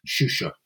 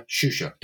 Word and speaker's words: Shusha: But actually, she had a diminutive, which the pronunciation is clear. It's Shusha. Yeah, Shusha, that's her diminutive.